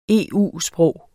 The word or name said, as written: EU-sprog